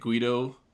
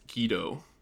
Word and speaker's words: Guedo: No, but I say it Guedo too. So it's actually, okay. I've been told my whole life it's Guedo. But yeah.